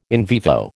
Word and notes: in vivo: American English

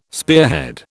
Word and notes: spearhead: British English

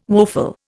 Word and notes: woeful: Indian

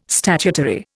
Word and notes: statutory: Indian

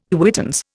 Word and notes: quittance: Indian

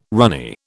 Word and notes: runny: British English

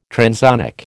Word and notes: transonic: American English

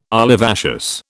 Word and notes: olivaceous: British English

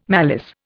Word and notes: malice: Indian

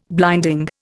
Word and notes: blinding: Indian

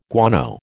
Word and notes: guano: American English

